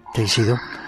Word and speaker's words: Teixido: Hay un lugar en Galicia increíble, San Andrés de Teixido, o Teixidó, no sé cómo se dice. – Teixido.